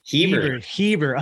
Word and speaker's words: Heber: And where's, is it Hader City? Is that how you pronounce it? – Heber. – Oh, so close.